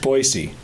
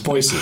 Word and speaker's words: Boise: Oh, Treefort, in Boise. – Okay. – Yeah, we're basically festival band now. That's what we do. – Guys, it's pronounced Boise. It's Boise, not Boise.